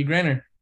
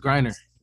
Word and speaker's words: Griner: I feel like Brittany Griner. – It's Griner. You gotta get her name right if you're gonna do, you know what I'm saying? – That was Griner.